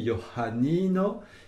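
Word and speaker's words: Johanino: Alors, Johannino, c'est un peu difficile effectivement de prononcer le H aspiré, Johannino, et puis d'accentuer le ni, l'avant-dernière syllabe.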